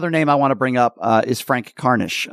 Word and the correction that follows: Harnish: a personal friend of mine, Harnish. I always pronounce his name wrong.